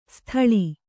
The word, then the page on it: ಸ್ಥಾಳಿ: sthāḷi; Kannada